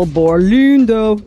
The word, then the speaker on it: Borlindo: That's the archaeologist Michael Borlindo, who led the, that's how you got to say his name. It's kind of Scandinavian. There's like a line through the O.